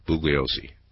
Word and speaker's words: Bugliosi: Tomorrow night, Vincent Bugliosi, I think I'll try to get it right by tomorrow night, Bugliosi. The G is silent.